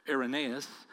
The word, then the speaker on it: Irenaeus: there was a man named Irenaeus, or some pronounce his name Irenaeus, who was an early church father.